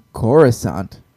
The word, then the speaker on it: Coruscant: We only did how's it pronounced again? – Coruscant. Huh? – Yes. – Huh? – That's the one. You got it.